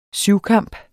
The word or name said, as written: syvkamp